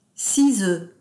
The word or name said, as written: six oeufs